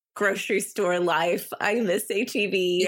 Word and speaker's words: H-E-B: M-E-I-J-E-R. – Yeah, that sounds right. – Okay, the one that's down near you, it's H-E-B. Is that how you say it or is it Heb? – Oh my goodness. No, it's H-E-B and it is...